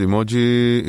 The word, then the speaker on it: emoji: אז ככה, the word emoji is pronounced e-mo-ji, כלומר, e-mo-ji?